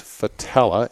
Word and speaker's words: Fattaleh: My first name is Kip, K-I-P. And my last name is Fatella, and again, it's spelled F as in Frank, F-A-T-T-A-L-E-H.